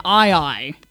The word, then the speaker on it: aye-aye: There is an animal native to the island of Madagascar called an aye-aye. And, you know, like aye-aye captain, and that's how it's spelled.